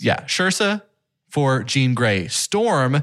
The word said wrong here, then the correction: Saoirse: I don't think you've ever said her name right. Yeah. Ever one time. – Saoirse.